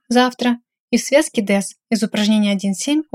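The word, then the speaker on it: дэс (です): При редукции гласный почти не произносится, хотя вы как бы собираетесь его произнести, но без голоса. То есть «дэщита» будет звучать как «дэщита», а «дэсу» – как «дэс».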